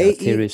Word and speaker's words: Aetherius: And if I have the correct pronunciation, the Aetherius, is that right? – Yeah, Aetherius Society, yeah.